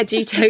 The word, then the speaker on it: Jutoh: I use Juto. – Oh, okay. Wow. Old school. – I hope it's pronounced like that. – Yeah, Juto. Yeah. J-U-T-O-H for people listening.